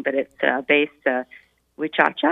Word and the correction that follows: Huachuca: or something like that. – No, Fort Huachuca.